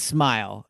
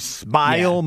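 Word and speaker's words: Smael: So speaking of it, the Vic Fangio impact on this draft, Smile Mondon? Do we pronounce it like? – I believe it's Smile is what I was told, but linebacker Smile Mondon.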